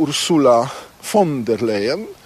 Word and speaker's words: Ursula von der Leyen: Ja bym po pierwsze powiedział Ursula von der Leyen.